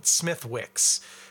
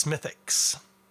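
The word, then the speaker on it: Smithwick's: And you probably call it Smithwix. But in most cases, we don't pronounce the W in those British place name pronunciations, or Irish in this case. So, for instance, it's not Chiswick bitter, it's Chiswick. And it's not Smithwix, it's Smithix. I don't know why they don't pronounce the W.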